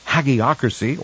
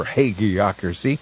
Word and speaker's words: Hagiocracy: It is Hagiocracy, or Hagiocracy. H-A-G-I-O-C-R-A-C-Y. Either pronunciation is acceptable.